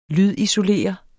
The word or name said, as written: lydisolere